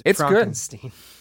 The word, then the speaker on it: Frankenstein: I did not watch the short, and it's pronounced Frankenstein.